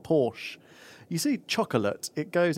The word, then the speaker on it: Porsche: Hang on, say that again. – Porsche. – Porsche. Porsche. I don't like Porsche. – That is the correct pronunciation of the automobile, isn't it? – Luke doesn't like Porsche.